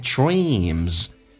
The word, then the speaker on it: dreams: Fulfilling the dreams，长元音，嘴角咧到极限， dreams，后面加 s 发 z 这个发音。